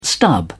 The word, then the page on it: stub: British English